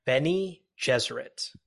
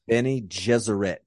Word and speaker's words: Bene Gesserit: Bene Gesserit. – Bene Gesserit.